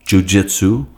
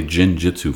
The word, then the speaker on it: jiu-jitsu: See, that's when you're dedicated to your craft, like Chris with guitar playing, like Sandar Brown with jiu-jitsu. I can never say that right. Say it right. – Jiu-jitsu, yeah. – Jiu-jitsu. I always want to say jin-jitsu for some reason.